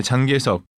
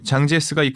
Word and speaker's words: 장제스: 지난 1949년에 장계석 중국 발음으로 장제스가 이끌던 중화민국의 국민당은 중화인민공화국의 공산당에게 내전에서 패배하면서 타이완으로 쫓겨갔습니다.